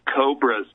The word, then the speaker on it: Cobas: Now, I said Cobras because it reads like Cobras. It's spelled C-O-B-A-S. They just took the R of Roche out of the name. It's Cobras, people.